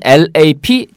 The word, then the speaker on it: lap: 그러니까 쉽게 얘기하면 무릎이라고 할 때는 LAP 짧게. – Lap. – 랩 이렇게 발음하셔야 돼요. 에 소리를. 그런데 LAB는 길게 발음합니다. 실험실.